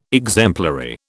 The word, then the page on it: exemplary: British English